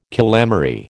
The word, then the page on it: calamary: American English